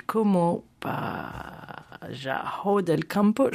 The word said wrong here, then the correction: Como el pájaro del campo: Je prononcerai mal espagnol. – Como el pájaro del campo. – Voilà, c'est mieux.